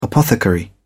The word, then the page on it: apothecary: British English